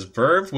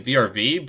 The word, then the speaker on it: VRV: I think people pronounced it as Verve, which I never knew. I just kept saying VRV, but Verve, I think, is what people call it for some.